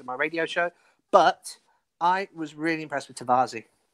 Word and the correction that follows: Tavares: Tavares. – I can't pronounce his name. Tavares.